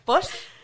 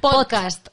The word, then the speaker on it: podcast: Post. – Post. No, pod, pod. Podcast.